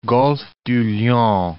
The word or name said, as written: Golfe du Lion